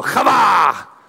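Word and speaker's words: qavah: Now say it like a Hebrew, kava.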